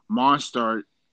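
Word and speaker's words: Mostert: Monstert, that's how you pronounce his name.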